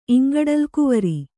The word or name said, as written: ಇಂಗಡಲಗುವರ